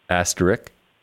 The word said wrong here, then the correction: asterisk: I'm sorry, asterisk. – Okay, well, so two things just happened there. At first, you said asterisk without an S, and then you said asterisk with an S.